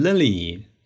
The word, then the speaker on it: Lily: 大家都会读成 Lily 啊，因为你没有注意到老外他其实读的不一样，老外读成的是 Lily，Lily，不是 Lily，是 Lily，Lily。所以呢，如果你呢也把它读成 Lily，那么老外一下子就会对你，哎，马上就会侧目对你啊，说，哎，不得了了，你的发音是下了功夫的。